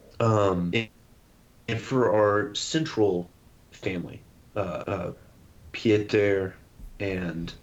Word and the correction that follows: Pietari: his father, I don't think I said his name right. – Pietari, and then Piparin. – Pietari, yes. Right, gotcha.